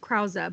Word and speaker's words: Krause: And then Peter Krause is the guy who's now on 9-1-1. He was in Parenthood. He's dating Lauren Graham. – Is Krause how you say his last name? – I've always heard it pronounced Krause by everyone who's like...